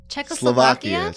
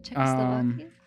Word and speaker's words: Czechoslovakia: Czechoslovakia. – Slovakia. – Yeah, you said it right. – Nice. – That was good. – Czechoslovakia.